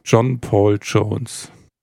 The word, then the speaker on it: John Paul Jones: Ja, Uff, eine Band mit John Paul Jones.